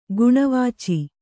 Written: ಗುಣವಾಚಿ

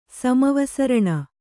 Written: ಸಮವಸರಣ

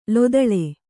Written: ಲೊದಳೆ

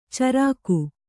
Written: ಚರಿಗೆ

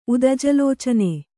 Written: ಉದಜಲೋಚನೆ

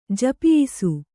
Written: ಜಪಿಯಿಸು

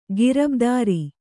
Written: ಗಿರಬ್ದಾರಿ

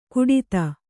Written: ಘಟ್ಟಿತ

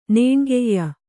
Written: ನೇಣ್ಗೈಯ

